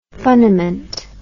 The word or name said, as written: funniment